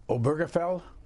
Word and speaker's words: Obergefell: Obergefell? – Yes, that's how you pronounce it.